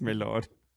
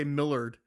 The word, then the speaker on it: Milord: I just said, M-I-L-O-R-D. Milord. – Milord. – Yeah. – They're going to say Millard.